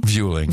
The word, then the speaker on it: Vueling: It was, how do you say the airline? Vueling? – Vueling.